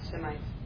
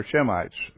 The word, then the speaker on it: Semites: Semites. – The Shemites. Semitic is talking about Shem. They're Shemites, or Semites, or Shemites, depending on how you say it.